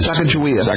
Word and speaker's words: Sacagawea: Sacagawea.